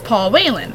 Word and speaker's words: Paul Whelan: Marine, his name was Paul Whelan. I believe I'm saying that right. – Yes.